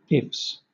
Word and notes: ifs: British English

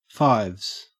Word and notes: fives: Australian English

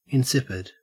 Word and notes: insipid: Australian English

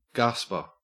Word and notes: gasper: Australian English

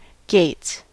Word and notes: gates: American English

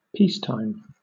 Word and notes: peacetime: British English

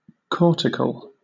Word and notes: cortical: British English

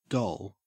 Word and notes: doll: Australian English

